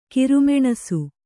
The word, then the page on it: ಕಿರುಮೆಣಸು: kiru meṇasu